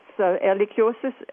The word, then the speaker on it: Ehrlichiosis: Let me ask you, what changes are – because the new disease, I don't know if I'm going to pronounce this correctly, is Ehrlichiosis. – Yeah, that's Ehrlichiosis is named actually after a German microbiologist who died a long time ago.